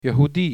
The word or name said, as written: yehudi